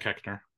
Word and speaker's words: Koechner: David Koechner. I think that's how you pronounce it. – Koechner. It's, Koechner, sure. Yeah.